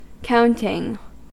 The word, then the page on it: counting: American English